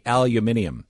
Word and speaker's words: aluminium: Aluminum would be how we pronounce it. How we will pronounce it as citizens of the UK will be aluminium. – Mm-hmm. Oh, you're over-egging the U.